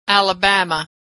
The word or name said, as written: Alabama